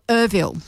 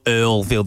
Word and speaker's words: Earlville: Also, Irville. That's removing a letter. Irville. – But Wangetti Wangetti. – Yeah, that one's a bit of a divisive one because usually when I ask people a pronunciation of a suburb and stuff that I'm a bit confused on that I've heard people say one way or another, there's usually a unanimous like, oh, it's more Earlville than it is Irville, right?